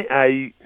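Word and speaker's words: Haüy: Donc, Valentin A-U. – Et ça s'écrit H-A-U-Y.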